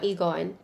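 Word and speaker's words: Lee Gon: So what we know now is that Lee Min-ho, who plays the emperor, is it Egon or Lee-gon? – Egon. You know, like how Korean surname E is like pronounced Lee if you write it in English, but I think this like subtitle, like they just pronounce it as E.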